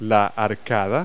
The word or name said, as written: arcade